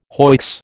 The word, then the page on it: hoicks: American English